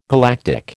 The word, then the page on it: galactic: American English